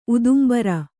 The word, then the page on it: ಉದುಂಬರ: udumbara